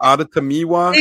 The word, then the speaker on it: Adetomiwa: I'm having the Bucks go with Mr. Adetomowa Adibawar. I probably butchered that, but Adetomiwa.